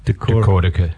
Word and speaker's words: Decortica: Decortica. – Decortica. Decortica rhymes with Metallica.